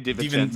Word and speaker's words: DiVincenzo: How do you say that guy's name? – Dante DiVincenzo.